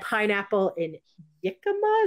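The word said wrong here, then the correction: jicama: Jikama with an H. J is pronounced H.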